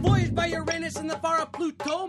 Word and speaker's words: Uranus: For the last time, it is pronounced Uranus.